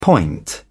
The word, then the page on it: point: British English